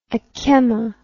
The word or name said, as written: ackemma